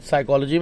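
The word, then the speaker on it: psychology: साइकोलॉजी में? – P.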